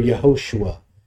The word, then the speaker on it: Yehoshua: Or it would be pronounced in the Hebrew Yehoshua, and it's pronounced in the Greek Iesu, and it's pronounced in the world today in various ways.